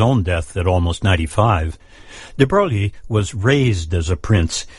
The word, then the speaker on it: de Broglie: He was Louis de Broglie, whose name the French pronounce more like de Broglie.